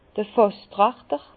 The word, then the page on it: d'r Fàsstrachter: Bas-Rhin; Herrlisheim